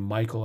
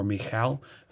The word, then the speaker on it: Michal: And I know a lot of people pronounce her name Michael. I think it's more Michal.